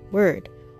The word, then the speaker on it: wyrd: Well, okay, discord, ruin, and rebirth was what they were going for, and the word that they encompassed around was literally word, W-Y-R-D, and was different from this word compared to the regular word that we hear, which is spelled W O R D, word that is spelled W Y R D.